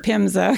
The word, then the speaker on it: PHMSA: Oh, that's how you say it, PHMSA.